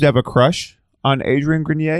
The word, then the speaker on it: Adrien Grenier: Okay, so they've got Eklo Labs, and then they were supposed to do this edutainment web series with Adrien Grenier.